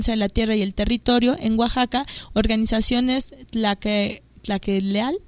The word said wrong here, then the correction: Tlacaelel: del DF, Tlacaelel. – No lo pronuncié bien,